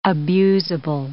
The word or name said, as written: abusable